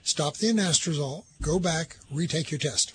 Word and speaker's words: anastrozole: So I'm taking 1/4 milligram, probably won't pronounce this correctly, Anastrozole.